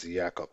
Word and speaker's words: Jacob: If an American, I would call him Jacob, but I believe it's Jacob is how they pronounce his name.